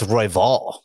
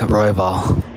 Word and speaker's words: Royval: So you the first time around, you emphasized the wrong syllable. Why don't we play the file for the masses? – Emphasis on the wrong syllable. – Brandon Raw Dog Royval. Brandon Raw Dog Royval.